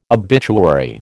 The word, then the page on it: obituary: American English